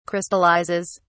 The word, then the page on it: crystallizes: British English